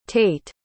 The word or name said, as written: tate